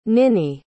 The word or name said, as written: ninnie